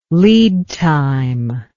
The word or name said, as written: lead time